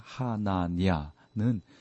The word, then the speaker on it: 하나냐: 거짓 선지자 하냐냐의 행동을 이제 여기서 보게 되는데 28장은 멍의 예언을 계속하고 있습니다. 거짓 선지자 중에 하나인 하냐냐는 좀 발음하기가 좀 그러네. 하나냐는 예레미야의 예언을 거절하고 진짜 하나님의 말씀을 준다라고 또 이제 백성들을 속이는 거예요.